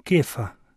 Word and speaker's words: Kefa: Za trenutek se ustavimo ob tem, ob dejstvu, da Jezus da Petru to novo ime, Peter, ki se jo v Jezusovem jeziku izgovori kefa, torej beseda, ki pomeni skala.